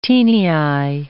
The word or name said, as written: taeniae